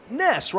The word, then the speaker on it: Ness: I'm like, Ness rhymes with Wes.